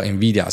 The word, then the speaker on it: NVIDIA: 那他会叫 NVIDIA，是因为他是那个应该是没记错吧，就是西班牙的 Envy 哈，所以是这样念。